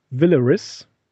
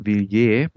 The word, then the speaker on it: Villiers: 1903 dann traf er Lady Beatrice Child Villaris, Würdest du das so aussprechen? – Villeris? Ich glaube, das ist eher französisch, Villiers. Villers. Villiers.